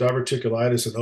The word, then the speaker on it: diverticulitis: Diverticulitis? How do you say diverticulitis? – Diverticulitis.